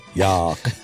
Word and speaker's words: Jaak: It's spelled J-A-A-K and then A-A-B. – Oh. – And I looked up how to pronounce it. I didn't want to get it wrong. – Wow, impressive. – You know how you can go to some website and you say, I had to pronounce this. And then some robot lady says, Yock. – Oh, yeah. – Yock. – Mm-hmm. – Yeah. Ob. So it's Jakob.